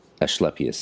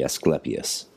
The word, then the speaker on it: Asclepius: So ASC is ash, would be creating AS, Asclepius sound. Whereas if it was classical Latin, it would be Asclepius, just like Trismegistus in ecclesiastical Latin and Trismegistos in classical Latin.